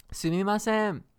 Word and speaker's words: Sumimasen: Sumimasen.